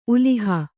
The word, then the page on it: ಉಲಿಹ: uliha